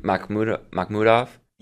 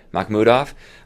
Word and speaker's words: Mahmudov: So it seems like this branding of the shark was built around, how do you say his last name? Mahmudov? – You got it. – Mahmudov.